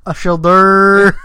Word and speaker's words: Ashildr: It's how it's pronounced. – No, it's not. Ashilder. – It's Ashilda. – Ashilda. Okay, it's spelled A S H I L D R.